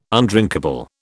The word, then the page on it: undrinkable: British English